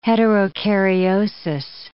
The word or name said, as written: heterokaryosis